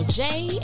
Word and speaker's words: Jaie: This is your girl, Jay, and welcome to the Jay Spot. And that Jay is spelled J-A-I-E.